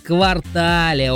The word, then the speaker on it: квартале: Существует мнение, Олечка, что данное слово в значении «часть города» произносится с акцентом на последний слог, а в значении «четверть года» на первую гласную «а». Однако это не так. В словарях зафиксирован лишь один верный вариант произношения этого существительного. И он с ударением на последний слог. По-ентому правильно говорить «квартале», Олечка.